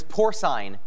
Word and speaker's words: porcine: This word that means resembling swine rhymes with swine. – What is porcine? – Correct.